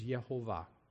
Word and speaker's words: Yehovah: and he says that the proper pronunciation used in their once a year holy, high holy day prayers is Yehovah.